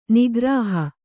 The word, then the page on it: ನೌಕಾರೋಹಿ: Kannada